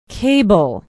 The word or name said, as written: cable